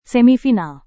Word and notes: semifinal: indonesian

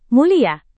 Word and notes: mulia: Indonesian

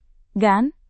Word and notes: gan: indonesian

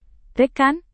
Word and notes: rekan: indonesian

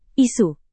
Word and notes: isu: indonesian

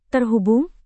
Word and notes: terhubung: indonesian

